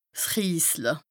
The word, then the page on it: 's Riesle: Bas-Rhin; Strasbourg